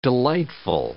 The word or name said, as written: delightful